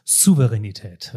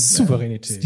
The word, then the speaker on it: Souveränität: Das heißt, Souveränität mit einem scharfen S am Anfang hat mir diese Sprechtrainerin eingebaut. – Souveränität.